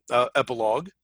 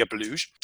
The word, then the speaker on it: epilogue: I mean, we have a few thoughts on the epilogue, or as I pronounced it as a young man who read a lot and never heard anything pronounced, the epiluge.